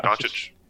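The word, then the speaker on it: Dončić: Doncic. Is that how you say his name? Yep, Doncic. Doncic, yep.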